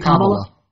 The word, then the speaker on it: Kamala: Well, Kamala, how do you say it? – Kamala. – I'm sorry. Kamala Harris.